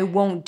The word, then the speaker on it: won't: In the word won't, we have the long O as in go. In the O as in go, there is a W at the end, O, right? If you don't add in that W, won't, with or without the T, it's going to sound like want.